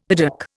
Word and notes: piddock: Indian

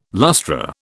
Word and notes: lustra: British English

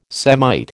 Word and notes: Semite: American English

